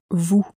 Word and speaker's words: vous: So, for instance, the word for you is vous. – Spelt V-O-U-S, but it's not pronounced vous, it's pronounced vous.